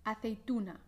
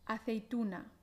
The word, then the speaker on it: aceituna: La T española no se aspira y la U aquí em, bien cerrada y tensa. Tú, tú, tú. Y vamos ahora con la última sílaba. Na na. Na. Aquí también recuerda la A abierta, ¿vale? Y bien clara. Na. A. Na. Aceituna. Aceituna.